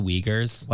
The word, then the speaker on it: Uyghurs: It's pronounced the Uyghurs.